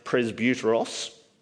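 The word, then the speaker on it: presbyteros (πρεσβύτερος): It's pronounced presbyteros, and it is the word that we get Presbyterian from, because we're a church that is ruled by elders.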